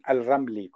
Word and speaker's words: Al-Ramli: ¿Lo he dicho bien, Muhsin? – Lo has dicho bien. Al-Ramli, con M.